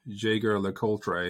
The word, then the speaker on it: Jaeger-LeCoultre: So it's Jaeger-LeCoultre, but it's pronounced. Okay. It looks like this: Jaeger-LeCoultre. If you're German, so that's one of them.